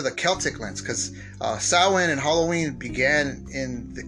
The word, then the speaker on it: Samhain: Halloween's roots date back to the ancient festival of Samhain, which is I don't understand. It's spelled S A M H A I N, but it's pronounced Samhain, like S O W I N.